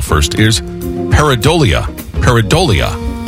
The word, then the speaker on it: pareidolia: P-A-R-A-D-O-L-I-A. Pareidolia. – So close. – Just a bit outside. – Really? – Yeah. P-A-R-E-I. Silent I in there. D-O-L-I-A. So pareidolia with a silent I in the middle of it.